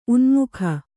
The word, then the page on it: ಉನಾಮಕ: unāmaka